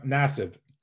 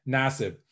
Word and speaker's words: Nassib: Nassib. – Nassib.